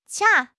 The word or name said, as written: 掐